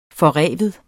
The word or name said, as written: forreven